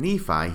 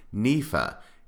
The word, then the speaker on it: Nephi: Instead of Nephi, he says Nepha.